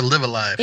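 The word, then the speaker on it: Live A Live: Is it Live Alive or Live Alive? – It is Live Alive. It is the same character for Live twice.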